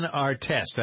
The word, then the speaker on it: Artest: The former Ron Artest, I pronounced his name correctly, Richie, right? – Yes.